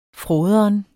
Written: fråderen

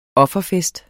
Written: OFFERFESTEN

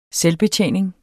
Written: SELVBETJENING

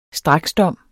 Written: straksdom

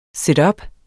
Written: situp

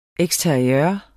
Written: eksteriør

